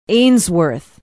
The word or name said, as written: ainsworth